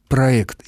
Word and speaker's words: проект: В слове «проект» ситуация иная, и русский язык распорядился этим словом по своему усмотрению. Произношение без звука «е» «проект» является единственно верным.